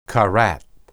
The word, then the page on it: karath: Hebrew